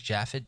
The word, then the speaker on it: Japhet: She was been identified by some as Deborah Leeds on grounds that Deborah Leeds' husband, Japhet Leeds, I guess you would pronounce that as Japhet, J-A-P-H-E-T, that's Japhet, wouldn't it? – I think so, yeah.